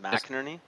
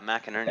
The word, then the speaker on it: McInerney: Like no one knows how to pronounce it. McInerney. You did it right, actually. Yeah, McInerney. Yeah. You totally pronounce it right, but most people, they say like McInerney, or like they're confused by it.